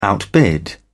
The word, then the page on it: outbid: British English